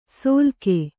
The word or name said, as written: ಸೊಲಗೆ